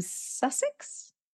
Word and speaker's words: Sussex: We're with Bex Maini, and she is from Sussex. – Yes. – Did I pronounce that right? – You did, yeah, near Brighton.